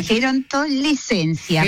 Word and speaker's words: Gerontolescencia: Su último libro es La Nueva Gerontolocencia. Lo dije bien, ¿no? Gerontolocencia. – Sí, sí. – ¿Lo dije bien? – Gerontolescencia.